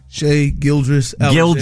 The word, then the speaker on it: Shai Gilgeous-Alexander: Gilgeous Alexander? – Shai Gilgeous-Alexander.